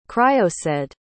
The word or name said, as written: criosaidh